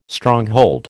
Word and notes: Stronghold: American English

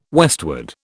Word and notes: westward: British English

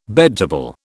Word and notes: bedtable: British English

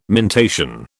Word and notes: mentation: British English

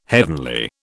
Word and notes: heavenly: British English

